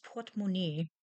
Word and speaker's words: Portemonnaie: Das Portemonnaie, die Portemonnaies, wallet, French pronunciation.